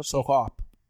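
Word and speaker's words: Soccop: I'm going to tell you, I think it's S-O-C-C-O-P. – S-O-C-C-O-P. I believe that's pronounced Soak Up.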